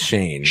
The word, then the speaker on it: Schoen: It's pronounced Schoen.